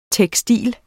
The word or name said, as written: tekstil